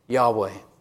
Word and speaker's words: Yahweh: If you look at, the year is 2026, Yahweh, the letters YHW in English we would put, but it's actually a V pronunciation in Hebrew, and the H on the end.